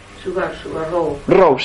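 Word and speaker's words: Subba Rao: ¿se escribe Subarrao? – Subarrao, se pronuncia Rao, gracias, Subarrao, vamos a decirlo con la pronunciación.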